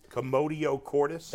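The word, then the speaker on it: commotio cordis: Commodio cordis? – That's it.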